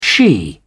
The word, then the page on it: she: British English